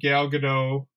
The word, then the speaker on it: Gal Gadot: And Gal Gadot, I said that right, correct? My wife's shaking her head yes, so I got confirmation.